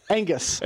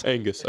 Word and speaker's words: Angus: Seventh place. Angus. – Angus, okay. – Sorry, Gus. – It's like the beef.